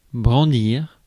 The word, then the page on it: wave: /ˈweɪv/; [ˈweɪv]